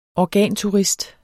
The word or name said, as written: organturist